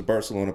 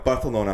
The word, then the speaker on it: Barcelona: So the story of him is he was a Barcelona player. If you're more cultured... – You said that wrong. – I know. Barcelona.